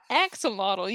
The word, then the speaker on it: axolotl: And she goes, well, what about that one that looks like an Oxwaddle? – No, it's an axolotl. You just butchered that.